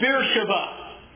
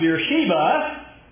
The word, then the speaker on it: Beersheba: This is Beersheba, sometimes pronounced Beersheba,